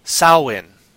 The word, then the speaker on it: Samhain: So, from what I understand how this is pronounced, the proper pronunciation is Samhain. Now, Celtic and Gaelic, Gaul, those languages is so hard to pronounce. So hard. Because they just... It's spelt this way. It's pronounced this way.